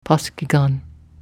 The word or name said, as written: paskhigan